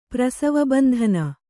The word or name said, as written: ಪ್ರಸವಬಂಧನ